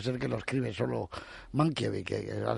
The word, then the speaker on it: Mankiewicz: En Polonia solo existe, en polaco, solo existen palabras graves o llanas. No hay esdrújulas ni agudas. De modo que es Mankiewicz. Mankiewicz. Mankiewicz. Y se pronuncia tal y como se escribe, un poco la alemana.